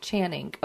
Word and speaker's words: Channing: Channing.